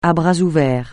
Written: à bras ouverts

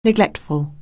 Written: neglectful

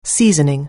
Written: seasoning

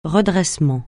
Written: redressement

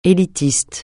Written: élitiste

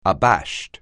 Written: abashed

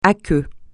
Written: aqueux

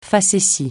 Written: facétie

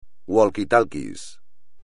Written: walkie-talkie

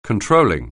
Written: controlling

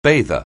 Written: bather

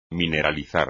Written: mineralizar

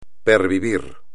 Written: pervivir

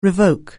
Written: revoke

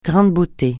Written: grain de beauté

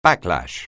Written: backlash

